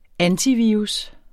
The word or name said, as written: antivirus